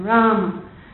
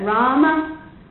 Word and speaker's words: Rama: And one thing also you should know is that Rama is a two-syllable word. So if we're going to chant all those 1,290 syllables, then we have to say Rama. In the Hindi language, there very often the A is cut off the end of words. So Indians very often will say Ram, but it's actually Rama. In Sanskrit, the A is pronounced.